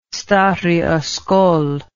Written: stary oskol

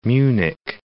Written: Munich

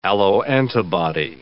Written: alloantibody

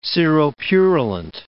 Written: seropurulent

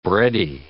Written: bready

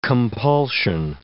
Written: compulsion

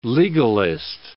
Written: legalist

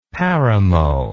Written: paramo

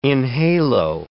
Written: enhalo

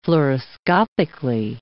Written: fluoroscopically

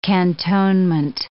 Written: cantonment

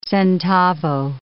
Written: centavo

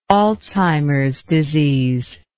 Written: Alzheimer's disease

